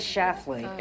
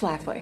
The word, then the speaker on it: Schlafly: Who the hell is Phyllis Schlafly? – It's Schlafly.